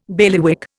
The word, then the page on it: bailiwick: Indian